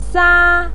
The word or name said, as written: sa1